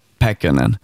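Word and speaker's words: Pääkkönen: Jag tänkte att vi måste prata om skådespelaren Jasper Pähkönen. Uttalar jag namnet rätt? – Pähkönen. – Pähkönen. – Mm. Ja. – Pähkönen. – Pähkönen. – Mm. Perfekt.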